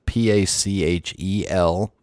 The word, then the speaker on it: Pachelbel: And the guy that composed it, here we go, speak English, was a composer and organist named Johann Pachelbel, and I've heard it spelled or pronounced a couple of different ways, but that's the one I'm going with, Pachelbel.